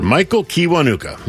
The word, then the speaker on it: Michael Kiwanuka: Big discussion about how to pronounce his name. I think most of us in the radio world were not entirely confident we were saying it the right way, but now pretty darn sure. Michael Kiwanuka.